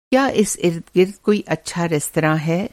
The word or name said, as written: इर्द-गिर्द